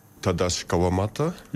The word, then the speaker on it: Tadashi Kawamata: D'ailleurs, son nom se prononce plutôt comme ça. – Tadashi Kawamata, artiste japonais.